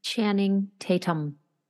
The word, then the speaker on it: Channing Tatum: You know, we all, it's not extraordinary for the Channing Tatum guy for being like, is that how you say his name? – Channing Tatum. – Thank you.